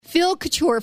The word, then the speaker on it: Couture: Phil Couture, first of all, am I pronouncing your last name right? – You are pronouncing it perfectly, yes.